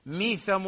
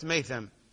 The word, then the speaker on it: ميثم: أعلم ذلك، ميثم التمار يقول، ميثم وليست ميثم، هكذا تقرأ وتلفظ باللفظ الصحيح.